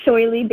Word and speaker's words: Shoille: My name is Shoily Banerjee.